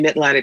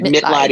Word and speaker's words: Mitleid: So it's C-O-R-B-I-E. C-O-R-B-I-E. Yep. – M-I-T-L-E-I-D. The word Mitleib is German. It means compassion.